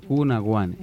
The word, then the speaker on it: Cuna Guane: En todas las plataformas digitales nos encuentran como Cuna Guane. Cuna, como cuna de bebé, y Guane, de los indios guanes.